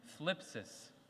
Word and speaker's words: thlipsis: Say it with me, thlipsis. You got to get the th.